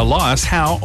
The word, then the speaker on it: Elias Howe: It was on this day in 1846 that the first sewing machine was patented by Elias Howe of Massachusetts.